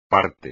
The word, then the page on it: parte: espagnol